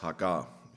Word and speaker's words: Hagar: Sie hatte aber eine ägyptische Sklavin namens Hagar. Oder ich glaube, richtig ausgesprochen heißt es Hagar.